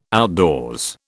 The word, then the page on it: outdoors: British English